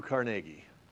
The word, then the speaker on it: Carnegie: Now, let me just, I used to live in Pittsburgh, and it actually is pronounced Carnegie. That's the correct pronunciation. How do I know? Because I got mocked by one of his relatives for saying Carnegie. If you see like a documentary about him, you'll see that the historians that are experts in him and in his life all pronounce it Carnegie, and the ones who don't know all pronounce it Carnegie.